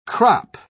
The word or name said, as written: crop